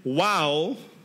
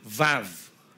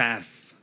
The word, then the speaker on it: Vav: And we're now talking about the Hebrew letter Wow, or how do you pronounce it? Vav. Everybody say Vav. Vav. Because Wow, W, is actually pronounced as V in Hebrew.